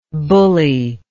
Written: bullyable

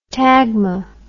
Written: tagma

